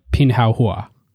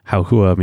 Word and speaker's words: Pinduoduo: We're going to do our best here, but Pinduoduo. – Which roughly translates as pin means doing something together, and Pinduoduo means good goods.